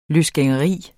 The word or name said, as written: løsgængeri